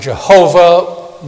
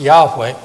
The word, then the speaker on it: Yahweh: Some pronounce it Jehovah. More literally, it'd be pronounced Yahweh.